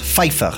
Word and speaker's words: Pfeiffer: O sea que se dice Pfeiffer, no Pfeiffer, porque la pronunciación alemana es Pfeiffer.